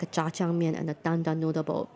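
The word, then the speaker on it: Jjajangmyeon (짜장면): Say it one more time slowly. – Jjajangmyeon. – Jjajangmyeon. – Yeah, there you go.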